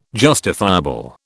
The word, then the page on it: justifiable: British English